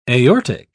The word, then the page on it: aortic: British English